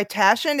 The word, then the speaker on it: Taschen: It's published by Taschen.